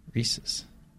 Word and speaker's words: Reese's: It rhymes with pieces. – Reese's.